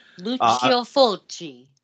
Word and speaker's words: Lucio Fulci: Lucio Fulci.